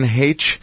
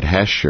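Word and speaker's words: Heche: Well, again, that was Dr. Nancy Haitch, and she is the mother of Hollywood actress Anne Haitch. And many people are pronouncing it Hesh or Haish or I don't know.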